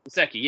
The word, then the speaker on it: Gesicki: Gesicki, yeah.